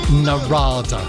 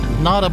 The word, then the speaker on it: Narada: How many people have I heard over the past couple of days calling it Narada? The R is silent. Nada, Michael Walden, featuring Dear Silas, Baby Let Go.